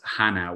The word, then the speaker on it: Hanau: Now, I'm not going to pronounce this incorrectly, but the way it comes across is Hanau, which I believe is near Frankfurt. Is that correct? – Yeah, that was very well pronounced.